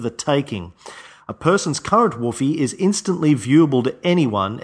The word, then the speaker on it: Whuffie: That word is Whuffie, W-H-U-F-F-I-E, pronounced like a dog, woof, woof. According to Wikipedia, Whuffie is the ephemeral reputation-based currency of Cory Doctorow's science fiction novel Down and Out in the Magic Kingdom.